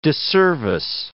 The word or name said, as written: disservice